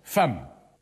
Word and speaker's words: femme: Un jour, l'instituteur nous a dit « F-E-2-M-E se prononce femme ». Mais j'ai dit à l'instituteur « Il n'y a pas de A dans femme ». Ah, bien vu, c'est exact, mais c'est comme ça. Le E de femme se prononce A.